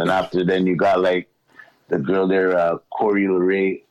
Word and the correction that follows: Coi Leray: I hope I said it right. Coi Leray. – Yeah, Coi Leray.